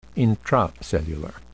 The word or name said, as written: intracellular